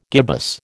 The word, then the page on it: gibbous: American English